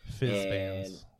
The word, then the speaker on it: Fizban: Yeah, so we're sticking with Fitzband's Treasury of Dragons for this. – Fizzband. – There's no T in there.